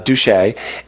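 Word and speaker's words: Duché: And they finally picked this guy whose name is Reverend Jacob Douche. Notice the accent, otherwise you'll pronounce this very wrong. Douche.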